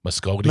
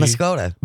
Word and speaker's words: Muscoda: I'm so glad I work here because I would have never known how to pronounce Muscaday because I pronounced it, I think, as like Muscogee. – Muscoda. – Muscoda. – That's how I pronounce it.